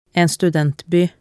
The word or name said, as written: en studentby